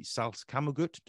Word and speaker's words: Salzkammergut: For anybody who's not aware, Rudolf wrote a piece on the Salzkammergut.